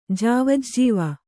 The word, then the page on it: ಜಾವಜ್ಜೀವ: Kannada